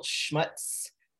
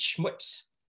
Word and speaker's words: Schmutz: And the next Sexy Times book I have, and I was pronouncing the title Schmutz, but someone corrected me and said that in Yiddish, it's Schmutz.